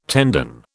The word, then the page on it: tendon: British English